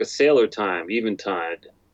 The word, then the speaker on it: Eventide: It is a tribute to the Eventide H9. – All right. That's a correct pronunciation, Eventide. I'm like, is it a vent? Is it tide?